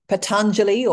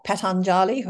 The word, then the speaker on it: Patanjali: So, according to Patanjali or Patanjali, however, however you like to pronounce his name, there are eight limbs to yoga practice.